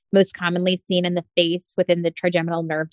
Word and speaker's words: PHACE: It is associated with large facial hemangiomas, and face is spelled P-H-A-C-E,